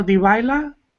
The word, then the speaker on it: Dybala: The Argentino Dybala. Is it Juventus? – Divio? – It's like D-Y-B-A-L, something like that.